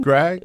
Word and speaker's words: Gragg: A good friend of ours personally, Vance Gragg of, am I pronouncing that right? – That's good. – Gragg? – Yeah.